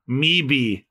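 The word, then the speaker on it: Möwe: It's German. It means seagull and it's pronounced Meebe.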